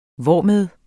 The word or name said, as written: hvormed